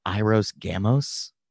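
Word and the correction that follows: Hieros Gamos: Hiros Gamos.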